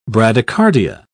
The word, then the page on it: bradycardia: British English